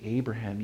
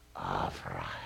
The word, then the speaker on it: Avraham: And if you're a Jewish person, you don't say Abraham, you say Avraham. Oh, and there is that thing. I listened to that name pronounced by Hebrews this week because I want to say it wasn't Abraham. It was Ah. And the B wasn't a B, it was a V.